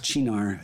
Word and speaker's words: Cynar: And then add 1/2 ounce I think it's Chinar is how you pronounce it. That's an artichoke based liqueur, which I have on hand. And that's it also. Cynar. And I think I used to call it like Cynar, but I heard someone refer to it as Chinar.